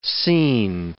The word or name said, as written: seen